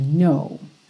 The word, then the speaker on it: know: Então, embora ele tenha esse K no começo, N-O-W, o K não é pronunciado e ele não vai ter a pronúncia do agora, now, aberta, uma pronúncia fechadinha, know, ok?